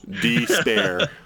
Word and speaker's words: D'Spayre: Despair. – But actually, it's pronounced despair.